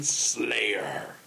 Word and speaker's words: Sleigh: Sincerely, the firefighting fox from Wisconsin, Slayer. And it's spelled S-L-E-I-G-H. Slay. – Mm. Like reindeer.